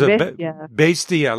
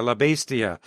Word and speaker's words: La Bestia: I talked a little bit about a story he did on the beast, and you still didn't tell me how to say it right. La Bestia? – Yeah, sorry about that. – There's La Bestia. La Bestia.